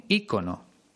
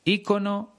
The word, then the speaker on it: ícono: Lo adecuado sería decir, a mí me gustaría que me recordaran como un ícono. Por cierto, en España casi todo el mundo pronuncia icono, aunque también están admitidas las dos formas, ícono e icono.